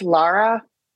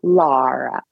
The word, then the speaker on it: Lara: I it's pronounced Lara. I get Lara, Laura. It doesn't bother me whatsoever. – But I want to get it right. – It's Lara.